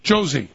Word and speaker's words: Josie: Let's talk to, oh my goodness, Zorzin? Say your name? – No, it's Josie. – Josie, okay. he spelled it wrong here.